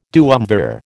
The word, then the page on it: duumvir: American English